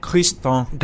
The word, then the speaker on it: Christin: Christin.